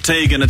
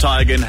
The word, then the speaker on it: Teigen: It's not Tegan, it's pronounced Tigan.